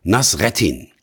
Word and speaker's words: Nasreddin: Der Hauptdarsteller dieser Geschichte heißt Nasruddin. Meine türkischen und arabischen Freunde sprechen den Namen allerdings anders aus. Nasreddin, Nasruddin Hoxha oder ähnlich.